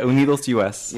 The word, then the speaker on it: UnidosUS: Yeah, UnidosUS.